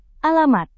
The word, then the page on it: alamat: indonesian